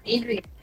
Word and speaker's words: Adrian: Tama po ba, Adrian, ang pronunciation po, Attorney? – Yes po, Adrian. Ayun, Attorney Adrian.